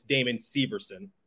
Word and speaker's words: Damon Severson: But I'll just say you can pronounce it either way, but the how they say it on air or on the PA system, it's Damon Severson.